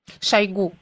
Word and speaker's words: Шойгу: Шойгу. Ударение на У.